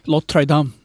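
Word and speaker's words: Notre-Dame: Notre Dame。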